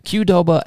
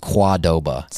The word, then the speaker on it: Qdoba: I said, how do you actually pronounce the name of this place? Because I've heard Qdoba and Kadoba. And she was like, oh, you're not going to like this. And I was like, what? She goes, it's actually properly pronounced Quadoba.